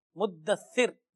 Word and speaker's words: مدثر: مدثر, یہ ہے اصل پروناؤنسیشن. اب اگر آپ اس کو مدثر کہیں گے اور پھر وہ سا کا بھی تلفظ نہیں سین کہیں گے تو پتا نہیں اس کا تو معنی بدل جائے گا.